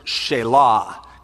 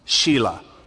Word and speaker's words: Shelah: The other was named Onan, and the other was named Shelah, or as we should pronounce it, Shelah, because no boy wants it pronounced Shelah.